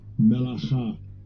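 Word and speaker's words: Melacha (מלאכה): Melacha.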